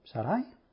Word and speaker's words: Sarai: Y lo que hice, escudriñé el término Sarai, es número 82, 97, número Strong's, se pronuncia Sarai, en el hebreo.